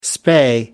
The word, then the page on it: spay: American English